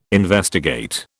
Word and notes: investigate: British English

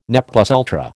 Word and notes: ne plus ultra: American English

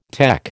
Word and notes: tec: American English

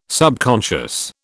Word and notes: subconscious: British English

